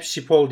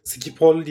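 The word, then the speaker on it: Schiphol: Havaalanının ismini ben hep Şipol diye telaffuz ediyordum. Skipol diye mi ediliyormuş? – Evet öyle. Öyle. Yani onu, onu da öğrenmiş şey.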